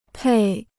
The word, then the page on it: 配: pèi